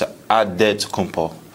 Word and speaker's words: Antetokounmpo: In the Nigerian way, you got to say it with a D. So it's Antetokounmpo. Yeah. But in the Greek way, you say it with an NT, like Antetokounmpo.